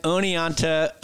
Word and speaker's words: Oneonta: You managed the 1995 Oneonta, did I say that right? – Yep.